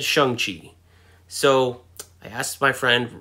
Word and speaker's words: Shang-Chi: Now you're saying, Brent, what is Shang-Chi? Well, that's how Kevin Feige pronounced it. Um, all this time, I thought it was pronounced Shang-Chi because it's spelled S H A N G C H I.